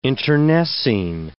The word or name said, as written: internecine